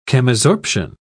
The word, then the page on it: chemisorption: British English